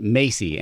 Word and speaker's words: MACI: It's called, we call it MACI, M-A-C-I.